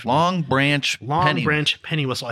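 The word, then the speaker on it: Longbranch Pennywhistle: I cannot pronounce that for life. – Long Branch Penny Whistle.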